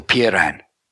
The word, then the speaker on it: Peiran: In the Ten Laws of Solon, the third law is to create an association of Peiran I'll spell that for you, Mel. P E I R A N.